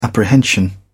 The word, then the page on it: apprehension: British English